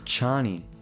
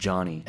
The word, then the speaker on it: Chani: Her name was Chani. – Yeah, Chani. That's what it's spelled like here. I didn't get that until the end of the movie. 90% of the way, I thought she was called Johnny.